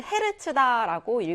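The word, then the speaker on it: Hz: 그때 진동수의 단위는요, 대문자 H, 소문자 Z 써서 우리가 HZ라고 쓰시면 되고요, 읽을 때는 헤르츠다라고 읽으시면 되거든요.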